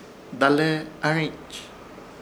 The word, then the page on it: Dalehearhĩch: Ishir Chamacoco